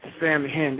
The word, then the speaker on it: Samhain: Samhain. It's spelled Samhain, S-A-M-H-A-I-N, but it's pronounced Samhain. And there you go. Samhain is how you pronounce that.